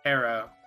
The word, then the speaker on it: tarot: Tara. The second T is silent.